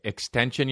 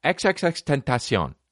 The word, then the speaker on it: XXXTentacion: You know it's XXXTentacion. That's how you pronounce his name. XXXTentacion. They asked him and he was like, it's actually XXXTentacion.